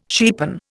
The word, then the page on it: cheapen: Indian